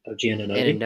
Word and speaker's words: Anunoby: OG Anunoby.